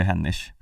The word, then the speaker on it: Hennig: Ja, nej, men det är ju Hennish, ska jag säga.